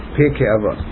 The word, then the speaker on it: Pirkei Avot: Pirkei Avot.